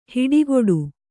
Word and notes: ಹಿಟ್ಟುಗುಟ್ಟು: hiṭṭuguṭṭu

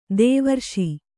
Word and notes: ದೈವರೇಷೆ: Kannada

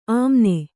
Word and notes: ಅಮ್ಮಣ್ಣಿ: ammaṇṇi

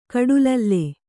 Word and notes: ಕಡುಲಲ್ಲೆ: kaḍulalle

